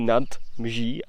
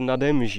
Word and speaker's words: nade Mží: A skutečně tam není nad Mží, ale nade Mží. – No, aby se to líp vyslovovalo.